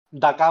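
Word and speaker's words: Daka: Και έχει πιο επιθετικά Red προς τα ειδικά ο Ντάκα, Ντάκα όπως προφέρεται ας πούμε.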